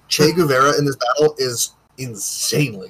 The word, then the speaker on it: Che Guevara: Che Guevara. – Yeah, Che Guevara.